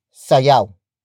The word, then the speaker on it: sayaw: It comes from the root word sayaw.